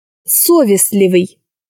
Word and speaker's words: совестливый: Совестливый. На букве О поставлено ударение. В скобках сочетание букв СЛ. Это означает, что непроизносимое согласное Т не произносится.